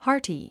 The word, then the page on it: hearty: hάː r ti; ハー ティ